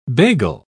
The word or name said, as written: bagel